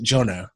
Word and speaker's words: Ionot: He's actually from Romania, and his name is, you pronounce it Jonot. It's spelled I-O-N-O-T.